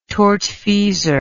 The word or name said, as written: tortfeasor